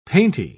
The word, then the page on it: painty: British English